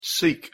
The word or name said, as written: seek